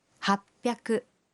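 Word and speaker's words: hapiaku (はっぴゃく): Y 800 se dice hapiaku.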